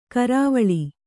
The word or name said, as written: ಕರವಾಲ